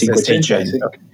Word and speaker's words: Cinquecento: Oh yeah, our pronunciation is Cinquecento.